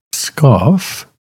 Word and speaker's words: Pskov: Our overture this morning is music by Rimsky-Korsakov from his Orchestral Incidental Music, The Maid of Pskov, P-S-K-O-V. Yeah, it seems like we could add another syllable or two in there, doesn't it? Another vowel.